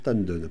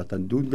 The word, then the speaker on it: Tan Dun: Oui, puisqu'il y aura une œuvre de Tan Dun, alors on prononce Tan Dun apparemment et pas Tan Dun, comme je dis, Secret Language of Wind and Birds, autrement dit le langage secret du vent et des oiseaux.